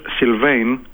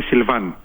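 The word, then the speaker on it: Sylvain: θα τον ακούσει να το προφέρει ο ίδιος Σιλβέιν, αμερικάνικα δηλαδή το όνομά του, αλλά στα γαλλικά είναι Σιλβάν, με αλφα.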